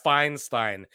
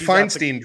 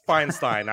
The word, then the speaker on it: Feinstein: I think it's Feinstein. – It's Feinstein. I heard her pronounce it. It's Feinstein. I would think she knows how to pronounce her own last name, but appreciate it nonetheless.